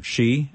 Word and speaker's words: Hsu: Grieving a Suicide, A Loved One's Search for Comfort, Answers, and Hope, the book written by Albert Shee. It's, his last name is H-S-U, but it's pronounced Shee.